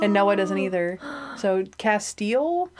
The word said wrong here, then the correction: Castiel: Castiel! – Oh, Castiel. Oh, so I was saying it wrong. My bad. Yikes. – Castiel.